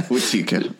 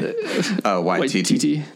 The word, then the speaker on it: Waititi: Watiki. – So you said, how do you say his last name? – Waititi.